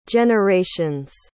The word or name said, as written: generations